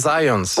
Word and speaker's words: Zajonc: c'est Zajonc. – Ouais? – Ouais. OK. J'ai-tu pas dit Zajonc? – Ben, j'avais dit plus Zajonc, mais. – Ah, ben, j'ai prononcé un petit peu à l'anglais. J'ai prononcé mon polonais à l'anglais.